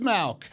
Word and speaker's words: Mauch: It is Mauch.